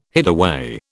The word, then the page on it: hideaway: British English